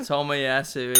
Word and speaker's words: Tomiyasu: Tomiyasu is correct.